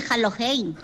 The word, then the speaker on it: Halloween: Pero me parece que no se dice así, se dice Halloween.